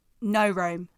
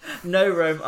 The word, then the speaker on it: No Rome: I don't really know who is it New Rome? – No Rome. – No Rome are. I've just absolutely butchered their name.